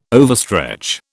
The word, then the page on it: overstretch: British English